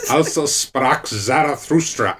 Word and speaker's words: Also sprach Zarathustra: And Strauss's Zarathustra? How do you say it? – Also Sprach Zarathustra.